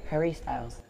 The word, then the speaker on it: Harry Styles: Sí, Harry Styles.